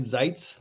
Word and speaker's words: Zaitz: Yeah, it's Brian Zaitz.